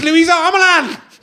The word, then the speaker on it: Luisa Omielan: I pronounced her name wrong backstage. She told me how to pronounce it, and now I've just got 1,000 names got 1,000 different pronunciations flying around in my head. It's Luisa Omielan.